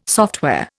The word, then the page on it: software: Indian